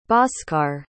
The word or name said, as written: bhaskar